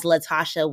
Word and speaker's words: Latashá: And then I'm on Spotify and iTunes and all the things as Latashá with an accent on the last A.